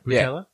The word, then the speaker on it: Boutella: Did I say that right? Boutella? – Yeah.